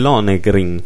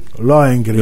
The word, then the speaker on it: Lohengrin: Tra l'altro sì, infatti proprio nel 1871 il Teatro Comunale fu il primo a rappresentare in assoluto un'opera di Richard Wagner, il, Long, il Lohengrin. – Lohengrin. Lohengrin. Lohengrin. Eh, vedi, tu c'è la pronuncia migliore, io sono, devo ancora imparare tanto.